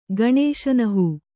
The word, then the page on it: ಗಣೇಶನಹೂ: Kannada